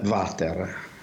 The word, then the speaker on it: water: Ecco, per esempio, noi diciamo il water, no? Cioè pronunciamo quella W che in realtà è una U, come un po' quella di uomo, tanto per capirci, una U semiconsonantica, però lo pronunciamo come se fosse V, e quindi diciamo il water, il Winchester, anche se è Winchester naturalmente, il Windsor, va bene?